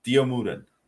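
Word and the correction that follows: Dio Maddin: Oh, Dio Madden. – Madden, thank you.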